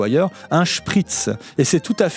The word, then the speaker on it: Spritz: se prononcent avec une chointante, Spritz, comme dans les mots Schluck ou Schlapp. Schluck désignant une gorgée, Schlapp des chaussons, donc on boit un Spritz. En français, la graphie SP se prononce avec une sifflante, à témoin la prononciation des mots spéologie, spécificité. Il est donc légitime, quand on est français comme moi, de dire Spritz.